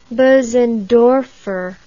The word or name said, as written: bosendorfer